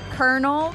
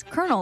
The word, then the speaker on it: colonel: I think I'm going to go with kernel, because it's actually spelled like colonel, but it's kernel, like a popcorn kernel.